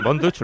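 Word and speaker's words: Von Dutch: Así que, Bon Dutch, ¿lo he pronunciado bien? – Sí, muy bien.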